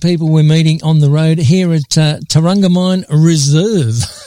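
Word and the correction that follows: Terramungamine: Did I say it right? – Terramunga mine. Very, very close.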